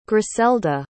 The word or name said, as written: gryselda